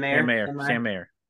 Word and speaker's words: Mayer: And Meyer is kind of, he's not on a make or break here, but he's gonna be Meyer. I don't know how you say it. – Sam Mayer. Yeah, because it's Mayer, not M-E-R.